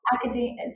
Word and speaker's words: Akademeia: Oh, Akademeia. So am I saying that correctly, by the way? – You are.